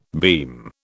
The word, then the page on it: beam: British English